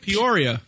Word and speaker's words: Peoria: Here's a, was it Peoria? Am I pronouncing that right? – Peoria. – Peoria.